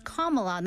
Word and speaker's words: Kamala: It's Kamala, not Kamala, as her opponent continued to say.